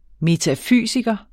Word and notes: metafysiker: metaˈfyˀsigʌ; ˈmeːtafyˀsigʌ

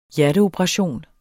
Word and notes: hjerteoperation: dansk

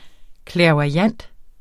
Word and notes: clairvoyant: [ klεɐ̯vwɑˈjanˀd ]; [ klεɐ̯voɐ̯- ]

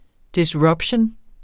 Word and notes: disruption: disˈɹʌbɕən; disʁubˈɕoˀn

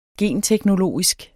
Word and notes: genteknologisk: ˈgeˀn-

